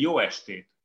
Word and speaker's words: jó estét: here, so you would say jó estét. Good evening.